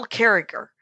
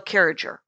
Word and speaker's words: Carriger: Her name is Gail Carriger. And it's spelled Gail Carriger, but she goes with a hard G on the second G.